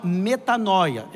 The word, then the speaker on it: metanoia: Arrependimento é uma palavra grega que no Novo Testamento, que é traduzido a partir do grego, se chama metanoia, é assim que se pronuncia a palavra arrependimento. Metanoia, que é mudança de mente.